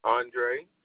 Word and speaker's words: Aundre: Andre. – Andre, how you spell it? – A-U-N-D-R-E. – A-U-N-D-R-E.